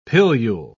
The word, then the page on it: pilule: British English